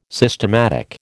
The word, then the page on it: systematic: American English